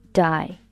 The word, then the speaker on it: do I: 这里 do I 可以把它读成 die, die.How do I address you? How do I address you? Address, A-D-D-R-E-S-S, 名词可以表示地址，动词可以表示称呼某人。How do I address you?